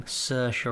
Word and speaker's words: Saoirse: Mainly the main girl, Jo, played by, we just looked up how to pronounce her name, Saoirse Ronan. – Yeah. – Look at us, because it's spelt like, it's like S and then like seven vowels.